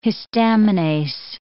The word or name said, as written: histaminase